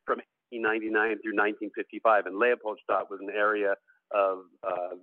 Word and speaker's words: Leopoldstadt: i guess the correct pronunciation is a leopoldstadt but Leopoldstadt. Approximating that is acceptable.